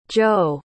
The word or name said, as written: tjode